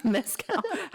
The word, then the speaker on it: mezcal: Mezcal.